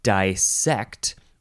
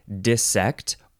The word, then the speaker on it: dissect: Okay, let's talk about the first word in this episode, which is dissect, or dissect, or dissect. Emphasize either syllable, and the first syllable can be di or di, dissect. D-I-S-S-E-C-T.